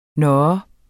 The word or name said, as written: nå